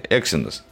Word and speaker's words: Exynos: Почему ты постоянно называешь Exynos? Какой-то нос. Э, Exynos. На первый слог ударения.